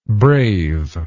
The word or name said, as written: braveness